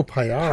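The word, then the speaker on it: Paillard: So it goes P-A-I-L-L-A-R, mystery letter that we don't pronounce because it's in French. It is D. – Oh, payar.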